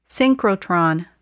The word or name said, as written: synchrotron